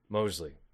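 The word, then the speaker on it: Moseley: Mosley.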